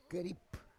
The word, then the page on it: kerip: Roon